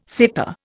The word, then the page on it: seta: Indian